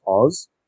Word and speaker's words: Oz: So, like, if you pronounce that, it's Oz.